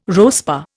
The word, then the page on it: RoSPA: Indian